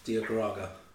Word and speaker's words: Diagouraga: Diogaraga.